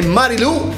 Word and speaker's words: Marilou: Incontriamo Daniela Cavanna con la canzone Marilou e l'accento sulla U. L'avete pensato, lo so. Quando ci sono le parole che finiscono con l'accenti, viene proprio da dire Marilou, l'accento sulla U.